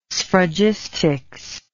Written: sphragistics

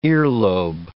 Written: earlobe

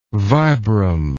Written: vibram

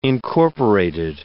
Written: incorporated

